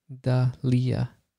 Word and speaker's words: Dahlia: It's Da Healy. Am I saying it wrong? It's Dahlia. It's Dahlia. Well, anyways, I bet you still probably get the Delilah thing all the time, too. Dahlia. Hey there, Dahlia. Like the flower.